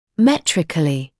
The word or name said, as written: metrically